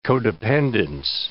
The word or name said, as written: codependence